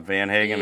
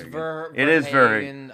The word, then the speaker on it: Verhagen: Anyway, so Nick Wittgren, Zach McAllister, and Drew Verhagen. I'm saying that right? It's not Van Hagen. – Yeah, it's Verhagen.